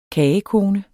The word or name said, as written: kagekone